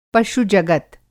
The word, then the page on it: पशु-जगत: Hindi